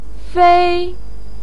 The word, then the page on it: fei1: pinyin